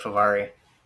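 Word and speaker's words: Fehérváry: How do you say his name? – I always say it Favari.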